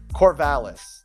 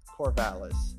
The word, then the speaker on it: Corvallis: Is it Corvallis? Where's Oregon State? Corvallis. That's the name. Corvallis.